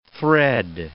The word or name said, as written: thread